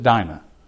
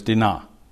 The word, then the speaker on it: Dinah: We pronounce it Dinah, but in Hebrew it's Dinah.